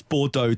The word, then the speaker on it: Bordeaux: Um, That's Bordeaux to any other.